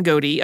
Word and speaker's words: Godey: So during these lean years, a man named Louis Antoine Godey approached Hale about moving to Philadelphia to edit his magazine. His name does appear French, but he was born in the US, so we're going with the Louis pronunciation.